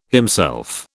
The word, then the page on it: himself: British English